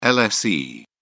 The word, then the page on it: lse: British English